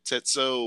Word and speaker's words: Tetsuo: I think his name is Tetsu or Tetso, or I can't remember how you say his name, but T-E-T-S-U-O. Yeah, Tetsuo. Yeah.